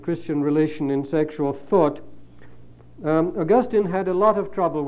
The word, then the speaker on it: Augustine: I call him Augustine because that's the British pronunciation, and I'm British. I believe that some of you say Augustine here, and you'll forgive me if I stick to my own pronunciation.